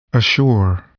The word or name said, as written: assure